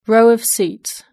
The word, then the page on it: row of seats: British English